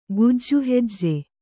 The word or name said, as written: ಗುಜ್ಜುಹೆಜ್ಜೆ